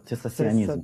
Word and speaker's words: цессационизм: По-русски это «цессационизм». Правильно, я произношу? – Да, «цессационизм». «Цессационизм». Ну, по-русски, да.